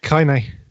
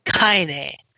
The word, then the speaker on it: Kaine: Laura Bailey is playing Kaine. I can't pronounce that. – Kaine. – Kaine.